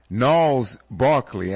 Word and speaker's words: Gnarls Barkley: This next one by Gnarls Barkley, and I believe I'm pronouncing his name correctly because it's spelled G-N-A-R-L-S Barkley. Mm-hmm. And I believe the G is silent. Correct me if I'm wrong. – No, you're right. You're 100% right.